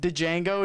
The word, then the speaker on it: Django: Dude, and then when the dude that's dying on the floor, he says Django, and he goes like, the D is silent hillbilly, and it just blows his head off.